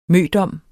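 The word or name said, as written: MØDOM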